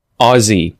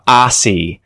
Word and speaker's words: Aussie: Americans tend to mispronounce this word all the time. They'll say something like Aussie or Aussie. So it's a bit strange. The vowel sounds off and they sometimes use an S sound. So, I mean, the spelling kind of suggests that's how it's pronounced, but I would say this as Aussie.